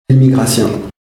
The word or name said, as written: immigratien